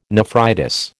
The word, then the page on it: nephritis: American English